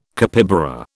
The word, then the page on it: capybara: British English